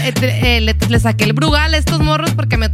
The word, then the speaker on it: Brugal: Y llevamos el, el Bruggle. ¿Cómo se dice? ¿A quién? Al Bruggle. Al Bruggle. Al Brugal.